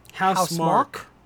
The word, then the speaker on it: Housemarque: Housemarque? Like his name, except it's spelled Q-U-E.